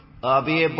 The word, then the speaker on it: Abhi: Abhi Barthakor. – I need you to spell it. – A-B-H-I.